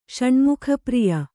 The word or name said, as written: ಷಣ್ಮುಖಪ್ರಿಯ